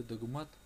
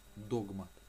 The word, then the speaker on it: догмат: В слове догмат ударение идет на второй слог, на букву А, а не догмат.